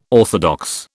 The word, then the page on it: orthodox: British English